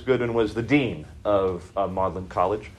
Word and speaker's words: Magdalen: That's spelled Magdalen College. M-A-G-D-A-L-E-N. Magdalen, pronounced Magdalen. I have no idea why.